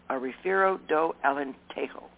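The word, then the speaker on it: Rafeiro do Alentejo: Refiero do Alento. Alentejo. A Refiero do Alentejo.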